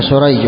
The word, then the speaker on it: سريج: سريج بن النعمان بالسين المهملة والجيم وهو من شيوخ البخاري، فهذا النوع الذي يقال له المتشابه.